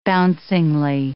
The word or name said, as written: bouncingly